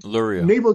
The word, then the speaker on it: Luria: Luria.